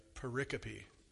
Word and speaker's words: pericope: It's called this unit is called a pericope.